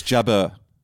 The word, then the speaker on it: Jabeur: It's Jabeur. It's not It's Jabeur. Sorry, yeah. Jabeur.